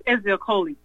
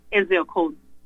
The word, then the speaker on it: Ezeokoli: Yes, Ezekoli. E-Z-E-O-K-O-L-I. Ezekoli.